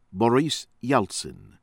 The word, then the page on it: ACHALOV, VLADISLAV: vlah-dih- SLAHF ah- CHAH -lawf